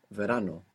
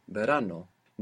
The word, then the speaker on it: verano: Maybe you look up the word in the dictionary for summer, which is verano or verano. Now, if you just program your mind with verano, maybe you won't get it when you hear verano.